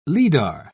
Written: lidar

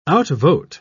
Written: outvote

